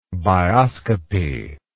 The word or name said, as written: bioscopy